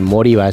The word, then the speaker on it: Moriba: No tiene más pronunciación, Moriba.